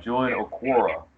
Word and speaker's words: Julian Okwara: I'm probably going to butcher this, so correct me if I'm wrong, but Julian Okwara from Notre Dame. – You nailed it.